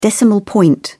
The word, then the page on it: decimal point: British English